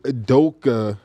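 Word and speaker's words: Udoka: My viral moment, Fax viral moment of the week is going to Ime Adoka. That's how you say it.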